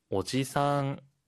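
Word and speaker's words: 오지상 (おじさん): 일본어에는 장음이 있어요. 그래서 길게 발음하고 짧게 발음하는 거에 따라서 의미가 달라지는데요. 지금 아저씨는 오지상. 길게 해서 오지상이라고 하면 할아버지. 그리고 오바상, 짧게 하면 아주머니. 길게 오바상이라고 하면 할머니가 됩니다. 여러분, 장단 꼭 주의하세요.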